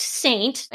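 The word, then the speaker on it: Seint: You picked Saint, and I'm going to spell that for our listeners. It's S-E-I-N-T.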